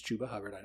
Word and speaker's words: Chuba Hubbard: For everyone listening, it's Chuba Hubbard.